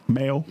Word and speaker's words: Mao: How do you pronounce M-A-O? Mao. – Mao. – Mao. – Mao. – M-A-O.